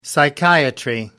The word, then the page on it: psychiatry: American English